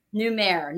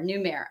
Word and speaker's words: Numair: Numair, new mayor. Okay.